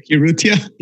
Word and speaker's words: Urrutia: Urrutia? – Urrutia.